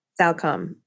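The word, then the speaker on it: Salcombe: Salcom. Is that how you pronounce it? Salcom? – Yes. No B. – Salcom.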